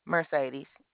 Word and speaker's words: Mercedes: Mercedes. – How do you spell that? Mer. – M-E-R-C-E-D-E-S. – C-E-E, two E's? – C-E-D, as in Delta.